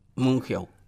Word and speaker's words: Mongeau: En la vez pasada estaba leyendo un comentario de un periodista llamado Monjo. – Mongeau. – Mongeau. – ¿Se, dice Mongeau? – No, no, Mongeau, pero... Se escribe Mongeau.